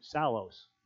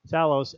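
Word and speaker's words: Psallos: Hey, you got this group Salos, begins with a P, silent P, Salos, and they take scripture and turn it into songs.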